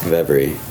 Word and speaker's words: kvevri: Well, it's translated as close as we can from Georgian to K-V-E-V-R-I, pronounced vevry, or sometimes they spell it with a Q. Q-V-E-V-R-I.